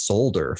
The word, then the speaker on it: solder: So the word solder, which is spelled S-O-L-D-E-R, I said that as solder for the longest time. But then my mom and my sister was like, no, it's solder. I'm like, it's solder? I'm like, why is there an L there? Like, I didn't understand it. I'm just like, why is there a silent L there?